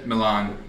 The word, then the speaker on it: Milan: Is it Milan? – Yeah. Milan. Yeah. But it's one of those Ohio things that we do things backwards.